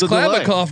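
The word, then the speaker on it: Kliavkoff: And first off, great pronunciation too, by the way. Have you been working on Klavikov? – You know, I'm drinking.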